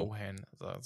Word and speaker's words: Sochan: Genau und damit quasi ihn neben, also Wemby neben Calvin Johnson, Devin Vassell, ähm, Jeremy Sochan, ja, ich, ich so weiß nicht, wie er ausgesprochen wird. Immer, immer noch. Sohan, soll man ihn aussprechen.